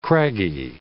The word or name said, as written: craggy